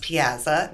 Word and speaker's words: piazza: And it's pronounced piazza, not piazza. Isn't that interesting? – go figure. Them. – Yeah. – Piazza. I like your research. – that's you just have to say it with a diphthong.